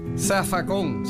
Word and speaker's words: zafacón: Zafacón. Se escribe con Z, pero se dice con S en puertorriqueño.